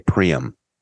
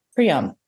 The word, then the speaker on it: Priam: How do you say it? – I say Priam. – Priam. That's what I say too.